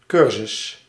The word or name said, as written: corso